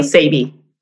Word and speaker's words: Sabi: Is it Sabi? – No, Sabi. – Sabi. – Wait, rhymes with baby.